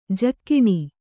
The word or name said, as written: ಝಗನೆ